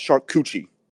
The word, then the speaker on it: charcuterie: It sounds right, but man, it's pronounced charcuterie. – Oh, yes, my bad. Charcuterie. – Charcuterie. We're having like a work thing Friday where it's like a cheese board thing, and like my coworkers were trying to pronounce that today because one of them was trying to bring it in. – Well, if they pronounce it charcuterie, that's wrong. Just tell them charcuterie.